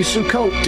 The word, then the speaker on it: Sukkot: Abe, happy Sukkot to you. Is that, did I say, pronounce it correctly? – Thank you. You nailed it. Perfect.